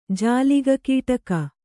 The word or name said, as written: ಜಾಲಿಗಕೀಟಕ